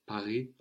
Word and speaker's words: Paris: In fact, in the Hunchback of Notre Dame, no, Notre Dame de Paris, of course, we don't pronounce the final S of Paris, of Paris, no.